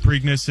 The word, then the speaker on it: Preakness: The Preakness? Preakness? – The Preakness.